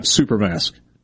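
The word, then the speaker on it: Xuper: So in conjunction with American technology company Honeywell, Will.i.am has introduced a super mask, spelled with an X, X-U-P-E-R, mask. Looks like super mask. It's actually super mask.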